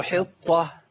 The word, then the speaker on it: حطة: التاء اللي عليها نقطتين دول مرققه اللي هي المربوطه تنطق تاء في الوصل عند الوقف. قول ايه؟ وقولوا حطة. تنطق هاء عند الوقف.